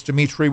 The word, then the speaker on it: Dimitri: It's Dimitri with three I's, D-I-M-I-T-R-I.